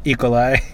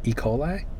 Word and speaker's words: E. coli: I've never heard anybody put the emphasis on the coli. E. coli. – Yeah, yeah. How do you say it? – E. coli? – E.